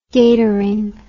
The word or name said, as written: gatoring